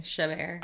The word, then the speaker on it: Chabert: Chabert.